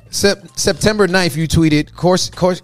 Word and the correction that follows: coercion: Coercion, sorry.